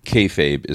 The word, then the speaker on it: kayfabe: So it says here, kayfabe is how you pronounce it.